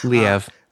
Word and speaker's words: Liev: And then playing her husband is Liev Schreiber as J.J. Kellogg. I probably mispronounce that name. I always do. – Liev. – Liev.